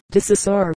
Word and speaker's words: De Saussure: De Saussure has become De Saussure, with the accent on the first syllable, and Priolia is called Prelo.